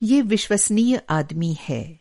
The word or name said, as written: विश्वसनीय